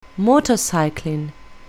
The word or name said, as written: motorcycling